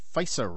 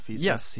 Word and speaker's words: FISA: So Counterspin was covering stuff on the FISA rulings. Is that how you say it, FISA? FISA? – Yes. Well, I mean, nobody was ever saying it before last week because we had no idea it existed.